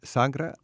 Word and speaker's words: sagra: Now, the key word, you said sagra? – Sagra. – How do you spell that? – S-A-G-R-A.